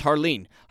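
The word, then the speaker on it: Jarlín: In my opinion, I think it's actually pronounced Harleen.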